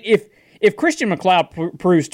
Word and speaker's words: McLeod: Well, that's Christian McLeod. I'm saying that right, right? – McLeod, yeah.